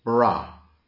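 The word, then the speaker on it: bara (ברא): The Hebrew word for created is bara.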